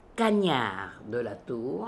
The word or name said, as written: Cagniard de la Tour